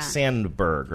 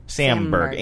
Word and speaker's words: Samberg: Am I saying that right? Sandberg, right? – Samberg.